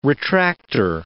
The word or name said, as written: retractor